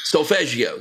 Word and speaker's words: solfeggio: So you said the word solfeggio, did I say it right? – Solfeggio, yeah.